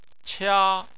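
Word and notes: qia: tone 1

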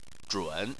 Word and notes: zhun: 3

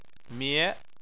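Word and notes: mie2: tone 2

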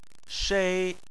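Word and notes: shei: tone 1

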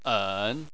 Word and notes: en: 3

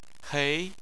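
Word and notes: hei: tone 2

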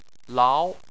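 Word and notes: lao: tone 2

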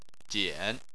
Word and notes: jian: 3

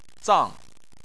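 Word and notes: zang: tone 4

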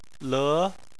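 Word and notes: le: tone 2

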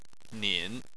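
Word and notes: nin: tone 3

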